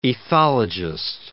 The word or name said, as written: ethologist